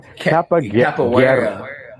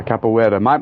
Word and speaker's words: Capoeira: oh my God, I can never pronounce this correctly. – It's a Capoeira. Yeah, Capoeira.